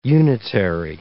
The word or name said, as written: unitary